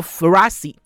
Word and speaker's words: Frassi: 这个新的餐厅就是我刚刚讲的 Frassi，F R。 A S S I 哈，我现在念给听众朋友听，听众朋友会发现意大利文哦是每一个字都要发音哈，那所以 F 就 Frassi 哈，他们都是这样念哈。